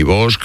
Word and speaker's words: Bosch: ni Bosch, ni...